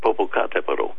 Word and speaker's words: Popocatépetl: Nothing specific, but they want me to ask you about Mount Popocatépetl volcano in Mexico. I don't even know if I pronounced that right. – It's Mount Popocatépetl.